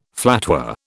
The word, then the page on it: flatware: British English